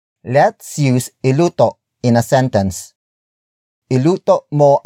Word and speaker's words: iluto: For example, the words iluto and lutuin. Iluto, lutuin. Iluto, lutuin. Iluto is spelled as I-L-U-T-O, while lutuin is spelled as L-U-T-U-I-N.